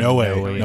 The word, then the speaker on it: Noé: How do you guys say it? – Noé. – Gaspar Noé. Noé. – Yeah, because he's got the accent on the E, right? – Yeah.